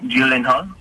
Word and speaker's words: Gyllenhaal: I just don't know how to pronounce Jake Gyllenhaal or Gyllenhaal. – Oh, you got it right the first time.